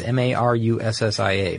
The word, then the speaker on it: Marussia: there's the I'm going to pronounce this maybe right, Marussia? Marussia? Mm-hmm. It's Marussia, Marussia. And they make a couple of supercars, really.